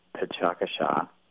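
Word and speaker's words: Pecha Kucha: It's pronounced Pechakasha. It looks like Pechakucha, but it's a Japanese word, and in Japanese it means chit-chat.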